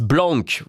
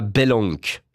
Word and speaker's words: Blank: Boris Blank, voilà, Yellow, pas Belenk, j'ai dit Belenk, Boris Blank.